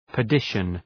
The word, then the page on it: perdition: pər’dıʃən